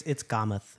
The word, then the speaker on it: Kamat: It's Kamath, actually.